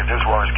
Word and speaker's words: VSWR: Now, that kind of error at very high VISWARs can take place. VISWAR, by the way, is an acronym for Voltage Standing Wave Ratio, VSWR. So lots of engineers will pronounce it SWR or VISWAR.